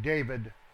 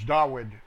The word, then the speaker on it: Dawud: Hard to break that long-time pronunciation saying David. It's not David, it's Dawud with a W.